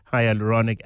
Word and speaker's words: hyaluronic: We'll be talking about why hyaluronic, that's how you pronounce it, hyaluronic acid is a key to a radiant, healthy skin.